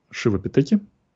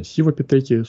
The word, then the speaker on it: сивапитеки: Шивапитеки можно назвать, можно сивапитеки. Собственно, пишется сивапитекус, но назван в честь бога индийского Шивы.